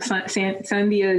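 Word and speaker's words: Sandía: Sandia is usually the way that people say it, is Sandia, because there's a little accent on the I.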